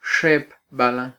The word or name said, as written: xepbalan